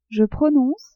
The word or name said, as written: je prononce